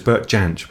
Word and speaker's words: Jansch: Yeah, it's not Bert Jansch, it's Bert Jansch, apparently.